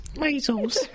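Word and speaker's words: Maysles: Maisels. You have to say it like that.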